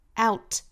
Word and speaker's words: out: What it's losing out on, out on, out, 尾部 t 又是 flap t, 跟刚才 at, 尾部 t 是一样的，都是 flap t 的发音。